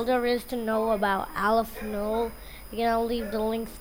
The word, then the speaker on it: Aleph-Null: The symbol is often pronounced Aleph-Null rather than Aleph-0, Probably because null is the word for zero in Georg Cantor's native language of German.